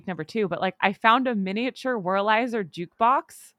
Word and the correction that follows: Wurlitzer: Did you say Wurlizer? – Wurlizer? How do you say Wurlitzer. – It? – Wurlitzer.